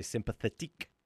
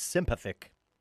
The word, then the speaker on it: Sympathique: I was going to say Sympathetique, but it's not. It's Sympathic.